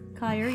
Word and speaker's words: Keir: Would you pronounce K-E-I-R, Kyre? – Kyre, So Kyre?